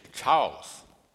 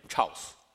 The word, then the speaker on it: Charles: You know, when I pronounce my name uh, a few years ago, so no one understand me. so I pronounce my name Charles. So right now, right now I learn better. My name is Charles. Okay, so finally I can pronounce L so I'm very joyful to have this opportunity to speak in Calvary Baptist Church.